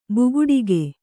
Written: ಬುಗುಡಿಗೆ

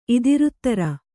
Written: ಇತರೇತರ

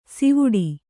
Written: ಸಿವುಡಿ

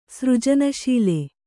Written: ಸೃಜನಶೀಲೆ

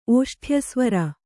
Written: ಓಷ್ಠ್ಯಸ್ವರ

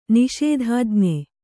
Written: ನಿಷೇಧಾಜ್ಞೆ

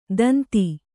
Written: ತಂತಿ

